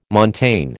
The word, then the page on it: montane: American English